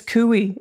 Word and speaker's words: Couy: My name is Cooey. It rhymes with Louie.